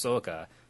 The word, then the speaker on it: Sokka: It's like, oh, and then they explain Sokka. It's like, oh, yeah, well, pronounce correctly, it's Soka, just from Japanese.